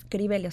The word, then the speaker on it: Crivelli: Y Risete, que en realidad se, pronuncia Richete, es recetas Cribeli, o sea, como mis recetas.